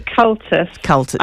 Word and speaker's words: Comhaltas: Now, if I pronounce this wrong, I apologise, it's Comhaltas, or how does that pronounce? – So it's actually Cultus, yeah.